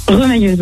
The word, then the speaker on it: remailleuse: Ça se prononce pas comme ça? Remailleuse? – Remailleuse, oui. – Ah, remailleuse, pardon.